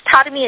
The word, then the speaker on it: Tarumi: Hi, my name is Tadami. It's spelled T-A-R-U-M-I.